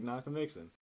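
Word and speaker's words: Nockamixon: And then Ed Prestera was like, "Hey, I'm doing this race at Lake Nakamalala." He didn't know how to say it. I was like, "Oh, Lake Nakamixon."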